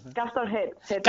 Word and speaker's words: Castor Head: Castor Head. Castor. De cabeza, así como Castor Head. – Sí, es como, como Motorhead, pero con Castor.